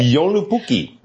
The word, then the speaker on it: Joulupukki: 他说在芬兰呢，这些圣诞老人其实有一个特殊的名字，叫这个。Again, can you say Joulupukki? – Right, that's the pronunciation.